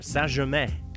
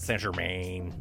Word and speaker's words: Saint-Germain: I'm going to do a French pronunciation here. Saint-Germain. Not Saint-Germain.